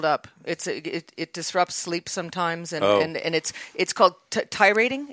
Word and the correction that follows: titrating: Titrating. – Excuse me, I didn't want to say that wrong.